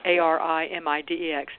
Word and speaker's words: Arimidex: He has taken oral meds, Clomid, and I don't know how to pronounce this one, Arimidex? – Mm-hmm. – Am I saying that right? – Yes. – Arimidex.